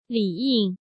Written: Li Ying

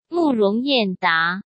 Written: Murong Yanda

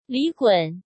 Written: Li Gun

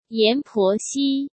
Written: Yan Poxi

